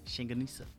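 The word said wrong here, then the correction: longganisa: Shinganusa. – Shinganusa. The little sausage. – Longanisa. – Longanisa, yeah.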